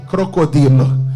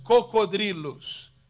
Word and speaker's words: cocodrilos: Yo dije crocodilo. Pero es cocodrilos.